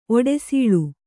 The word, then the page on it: ಒಡಸಲ್: oḍasal